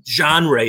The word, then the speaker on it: genre: I don't think that's how you pronounce it. – How do you pronounce it? – Genre, whatever. Is that English? – I think it's French, and I think that...